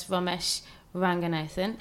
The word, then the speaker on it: Ramesh Ranganathan: which features Fabulous Ramesh Ranganathan. Perfect. I said that right, didn't I? – You did.